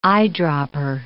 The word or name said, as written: eyedropper